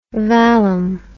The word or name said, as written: vallum